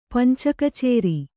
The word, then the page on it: ಪಂಚಕಚೇರಿ: kannada